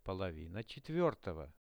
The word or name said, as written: polovina 4-ogo